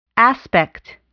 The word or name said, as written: aspect